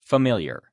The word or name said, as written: familiar